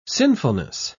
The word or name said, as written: sinfulness